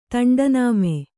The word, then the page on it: ತಂಡನಾಮೆ: kannada